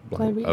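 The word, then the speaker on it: Bligh Reef: Bly Reef? Is that how you pronounce that? – Yeah, Bly Reef.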